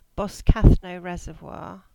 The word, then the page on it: Boscathnoe Reservoir: English